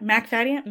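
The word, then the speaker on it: Macfadyen: I was like, is it weird. Mick? Is it Mac? It's McFadyen? McFadyen. – McFadyen.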